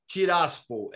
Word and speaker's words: Tiraspol: Tiraspol é a pronúncia correta.